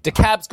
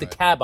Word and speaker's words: DeKalb: But DeKalb's got an L in it somewhere, but it's called DeKalb, I believe, rather than DeKalb.